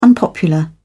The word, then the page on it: unpopular: British English